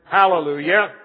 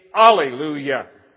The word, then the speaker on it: hallelujah: and that's the word hallelujah, or sometimes simply pronounced with a silent H, alleluia.